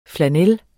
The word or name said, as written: flannel